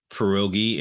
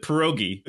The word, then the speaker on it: pierogi: What I found most fascinating about that story is that Katya pronounces pierogi in actual Polish instead of the way that, like, in Pittsburgh, you would be drawn and quartered. – Right, this might be massive Polish population. – No, yeah. They would pronounce it pierogi. The Pittsburghese, I think, trumps your native accent if you're from here.